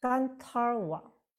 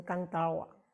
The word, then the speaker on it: 甘塔尔瓦: 接下来，甘塔尔瓦，甘塔尔瓦啊啊，有很多发音也有发成甘达瓦啊，甘达瓦。